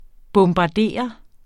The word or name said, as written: bombarde